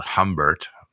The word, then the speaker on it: Humbert: That's Humbert pronounced, but spelled Humbert in English, but pronounced Humbert in French.